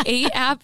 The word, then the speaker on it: ATE: So 8 app, it's not like the number 8. It's like you A-T-E. – Ate something. A-T-E. – You could just spell it. – Yep. – It's A-T-E.